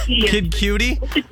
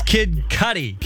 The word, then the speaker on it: Kid Cudi: Oh, Kid Cudi? – I don't know how to say it. – It's Kid Cudi.